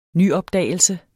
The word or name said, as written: nyopdagelse